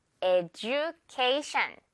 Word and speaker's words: education: 그렇게 발음하지 않아요. 영어 원어민들은 education 이렇게 발음을 한답니다. education.